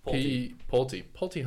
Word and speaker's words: Pulte: Well, a realtor, Pult Homes, I don't know how to pronounce it. Pulte Homes, there you go.